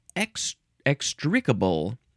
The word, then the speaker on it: extricable: Extricable. That is an adjective. You can also pronounce it extricable. Emphasize the first syllable.